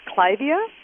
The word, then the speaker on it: Clivia: I think it's Clivia.